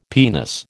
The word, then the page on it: penis: American English